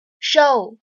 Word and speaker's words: show: 而有些同学呢，把它读成了展示 show show。 OK，展示这个单词当中呢，有一个双元音 o，相当于字母 o 的发音，所以呢，这两个单词务必注意区分。